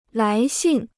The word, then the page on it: 来信: lái xìn